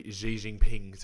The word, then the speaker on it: Xi Jinping: They want Xi Jinping to go, pretty much.